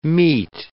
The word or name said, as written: mete